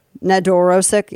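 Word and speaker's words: Nedoroscik: How do you say his last name? Steven Nedorosic? – Sounds right. – Nedorosic, yeah. So he's 25 years old, but he looks way younger.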